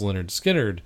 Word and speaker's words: Lynyrd Skynyrd: Like, uh, and he even played drums on Tuesday's Gone, which is on that first album, pronounced Lynyrd Skynyrd.